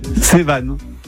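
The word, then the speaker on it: Sevan: Alors, on le prononce comment alors? – Pardon? – On le prononce comment? – Sévan. – Tout simplement.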